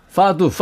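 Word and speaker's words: fado: 더 어려운 꼭 배우고 싶은 악기 중에 하나가 파도에 쓰는 악기죠. – 파두 파두. 예, 그래요. 예, 예 발음 예. 좋고.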